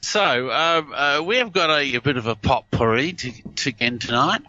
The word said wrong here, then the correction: potpourri: We've That's potpourri. – Oh, potpourri, is it? – Tea is silent.